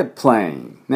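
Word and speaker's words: plane: エアー、この音ですね、喉の奥でエアー、エアーでプレインね、プップップダブルシーンです、プップップップップププレイン、プレイン、サイレント E ですから、 E は発音しなくて、 A の音が、アルファベット読みに戻ります。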